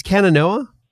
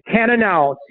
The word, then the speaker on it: Cannonau: Pronounce it again. It's Cannonau. – Cannonau, Cannonau.